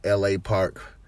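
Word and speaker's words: L.A. Park: The Park way they so pronounce it, I believe, is LA Park, is how I hear the announcer say it.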